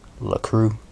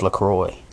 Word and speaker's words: LaCroix: That's how you say it too. It's written on the box or the can or the bottle like La Cru. It's LaCroix. I think it's La Cre if you're a big prick.